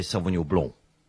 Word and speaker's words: Sauvignon Blanc: You see, if you're French, you say Sauvignon Blanc. I'm not French. I'm South African. And the correct way to pronounce the grape in South Africa is Sauvignon Blanc. And that is a hill I will die on, Solly.